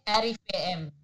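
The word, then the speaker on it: RIVM: And that's according to figures from the, how do you say this company? Rivim? – Arifem. Separately. Arifem.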